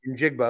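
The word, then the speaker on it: Njigba: I'd like to know how to pronounce his name, actually. – In Jigba. – Okay, I got pretty close.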